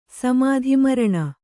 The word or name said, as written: ಸಮಾಧಿಮರಣ